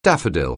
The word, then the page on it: daffodil: anglais